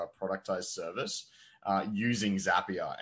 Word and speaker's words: Zapier: Zapier. Zapier like happier.